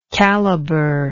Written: calibered

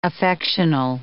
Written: affectional

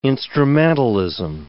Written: instrumentalism

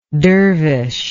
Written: dervishlike

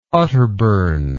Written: otterburn

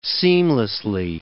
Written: seamlessly

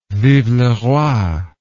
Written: vive le roi

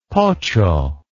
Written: pocho

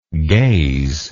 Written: gazingly

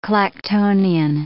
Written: Clactonian

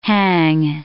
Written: hang

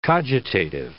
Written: cogitative

